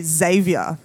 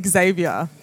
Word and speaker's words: Xavier: We say that different. I say Xavier. – Okay. – And North Americans say Xavier. – That's... – I know, isn't that funny? – Yeah, I mean, I was never conscious of it, but it's true. – It's true. Like, we say it like like a Z.